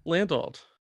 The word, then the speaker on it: Landolt: How do you pronounce it then? – What, my last name? – Yeah. – Landolt. – Landolt. How do people say it then? – Oh, like Landholt, Landol, like there's been a Landlot, like just they make up letters.